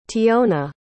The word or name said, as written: tiona